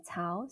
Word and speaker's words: Cao: So it's my first name, Effie, E-F-F-I-E, and then my last name, Cao, so it's spelled C-A-O.